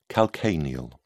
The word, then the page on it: calcaneal: British English